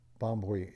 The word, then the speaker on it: Bombwe: There's like a little bit of an M-B-U-I. – Okay, Bombwe. – Yeah. – Bombwe.